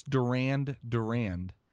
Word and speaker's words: Durand Durand: Duran Duran evidently is hoarding. – Yeah, I believe it's Durand Durand.